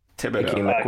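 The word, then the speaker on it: Thibodeaux: Thibodeau.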